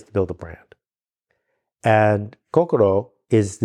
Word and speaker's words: Kokoro: Kokoro. – Kokoro.